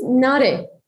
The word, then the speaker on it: Nare: My name is Nare, and I think you pronounce it quite good, so it's all okay.